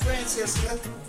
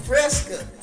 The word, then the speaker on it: Fresca: Fresca. Fresca, not Francesca. Fresca. Fresca.